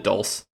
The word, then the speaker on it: dulse: D-U-L-S-E? – Yeah, I've always called it dulce.